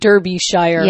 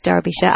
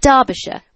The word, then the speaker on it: Derbyshire: The next question is from John in Derbyshire, UK. – Derbyshire. – It looks like Derbyshire. – Yeah, I know. – Okay. – It's Derbyshire. I know.